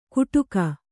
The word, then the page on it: ಕುಡುಕ: kannada